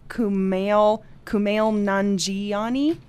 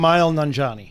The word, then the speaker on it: Kumail Nanjiani: Kumail Nanjiani. I'm not saying his name right. – Kumail Nanjiani.